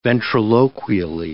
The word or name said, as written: ventriloquially